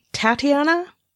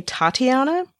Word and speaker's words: Tatiana: I think the Canadians say Tatiana, like a, you know, Tatiana, and Americans tend to say Tatiana,